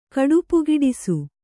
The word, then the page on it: ಕಡುಪುಗಿಡಿಸು: kannada